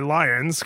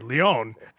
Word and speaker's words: Lions: So I'm going by Lions because l- my name, right? But it pronounced it the French way, Lyon. And I was like, no, pronounce it like the animal, L I O N S. And now it says it right.